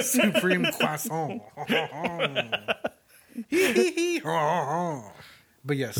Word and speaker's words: croissant: Sorry, croissant. I said it wrong. That's why she had That's why she didn't get it. – Because I didn't say it in French.